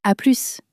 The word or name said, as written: a plus